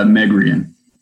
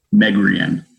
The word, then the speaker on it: Megrian: It's Megrian. – Say that again, please. – Megrian.